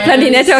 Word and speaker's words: Blondinate: Ihr bezeichnet eure Fans, die nie den Glauben an euch verlieren, als Blondinatoren. – Blondinate, okay, ich habe es nicht richtig ausgesprochen.